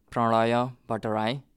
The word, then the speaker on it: Pranaya Batrai: My name is Pranaya Batrai, which is kind of simplified name in American version. The way it's pronounced in Nepalese is Pranaya Batrai.